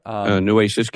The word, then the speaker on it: Nueces: Did I say that right? – Nueces County. – Nueces County, yeah.